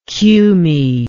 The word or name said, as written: cumae